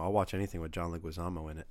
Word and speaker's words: Leguizamo: It's pronounced Liguizamo.